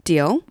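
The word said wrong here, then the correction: Deol: Deal. Deal? – No, Diol.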